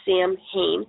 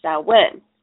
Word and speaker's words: Samhain: It is, the way it is spelt, it looks like Samhain. And I've heard people pronounce it this way before, but the correct pronunciation is actually Samhain.